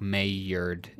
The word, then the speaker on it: Maillard: Like it looks like Maillard if I'm pronouncing the L's like a yeah. – You're correct. Yep. That's what it is.